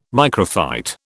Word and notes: microphyte: British English

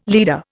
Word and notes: leader: Indian

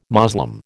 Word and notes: Moslem: American English

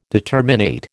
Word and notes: determinate: American English

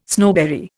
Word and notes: snowberry: Indian

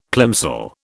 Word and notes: plimsoll: British English

